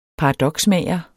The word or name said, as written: paradoksmager